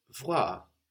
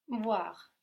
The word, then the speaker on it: voir: To see in French is voir, voir. – Voir. – That's spelled V-O-I-R, voir.